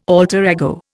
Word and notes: alter ego: Indian

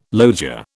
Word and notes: loggia: British English

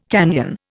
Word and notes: canyon: Indian

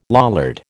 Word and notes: Lollard: American English

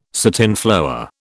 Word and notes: satinflower: British English

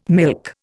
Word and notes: milk: Indian

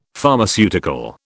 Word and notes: Pharmaceutical: British English

